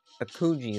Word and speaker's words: Akuji: My powers are weakening, Akuji. You must be swift. – Hang on one second. Akuji is how you say it.